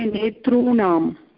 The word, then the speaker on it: नेतॄणाम्: न न हा नेत्रं पश्यतु पश्यतु, उच्चारणस्य समस्या अस्ति अत्र. उच्चारणम् अत्र नेत्राणां भवती उक्तवती, नेत्राणां. भवती वदतु किं स्यात्? – नेतारुकारान्तशब्दः. नेता शब्दस्य षष्ठीविभक्तिरूपाणि बहुवचने नेतॄणाम्. – अत्र कृष्णऋकारः अस्ति, अपि च दीर्घऋकारः भवति.